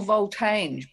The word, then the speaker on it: Voltange: I was thinking more Voltange because it's a mixture of vault, as in a repository, and ange, as in change.